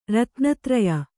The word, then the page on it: ರತ್ನತ್ರಯ: ratna traya; kannada